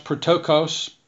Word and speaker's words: ptochos: Here the word is used, I think it's pronounced protokos, P-T-O-C-H-O-S, and it's, like I say, this is a word for the poor that indicates just a person who's just destitute, who's without.